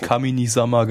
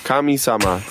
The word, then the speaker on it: Kamisama: Du hast Kaminisama gesagt. – Kamisama, sorry. Schade.